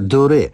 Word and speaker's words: Doré: Idzie o wymowę francuskiego nazwiska Doré, ponieważ nad E jest accent aigu. To należy je wymówić.